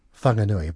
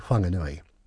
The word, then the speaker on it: Whanganui: But just so that things aren't so complicated, if you see a WH sound, it's pronounced as F. So Whakatane, Whangarei, and if you want to, Whanganui. But my preference is to say Whanganui.